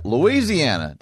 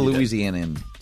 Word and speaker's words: Louisiana: All right, Louisiana. Did I say it right? – No, what a Louisianan say it.